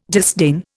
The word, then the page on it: disdain: Indian